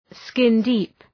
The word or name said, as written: skin-deep